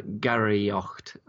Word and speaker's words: Gary Ocht: Okay, well, so what is, first of all, what is Gary Ocht? And have I pronounced that correctly? – Absolutely correctly, yes.